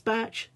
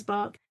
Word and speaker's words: Bach: Another thing that I like to use, something called bark flower remedies, B-A-C-H. Sometimes it's pronounced batch, sometimes bark. B-A-C-H, flower remedies.